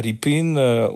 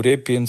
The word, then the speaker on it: Répine: si vous connaissez cette fameuse tableau de Hilaire Ripin ou Répine selon les prononciations, le peintre qu'on considère comme ukrainien, les Russes considèrent comme les Russes, mais il est plutôt des origines ukrainiennes, bien sûr.